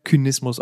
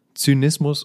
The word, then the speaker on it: Zynismus: Jahrhundert, ja, ist dieses C von Zynismus nicht mehr Kynismus ausgesprochen worden, sondern Zynismus